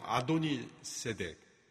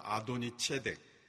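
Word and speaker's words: 아도니 체덱 (אֲדֹנִי־צֶדֶק): 이 예루살렘 왕 아도니 세덱, 아도니 히브리어 발음으로 하면 아도니 체덱, 아도니라는 것은 나의 주님이라는 뜻이고 체덱이라는 건 의로움이라는 뜻입니다.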